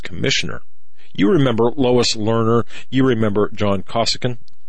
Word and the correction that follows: Koskinen: Yeah, John Koskinen. Yeah, that's how you pronounce his name.